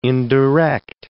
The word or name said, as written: indirect